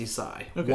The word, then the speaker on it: Ysaÿe: I know, I know, his last name is pronounced Ysaÿe. – Y S A Y E.